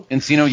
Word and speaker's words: Encino: So May 8th, 2006, in Encino, California. I think that's how you say it. I could be completely wrong. – Encino, yes. No, that's right.